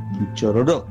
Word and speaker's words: Quichororó: El registro es Quichororó, con acento en la última O. Quichororó.